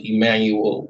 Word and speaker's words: Emmanuel: But to be honest with you, my name is spelled Emmanuel with a E instead of an I.